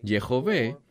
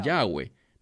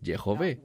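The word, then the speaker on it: Yehová: que apropiadamente se pronunciaría como Yehové, o lo que erradamente la gente que no habla hebreo pronuncia Yahweh. No es Yahweh, es Yehové.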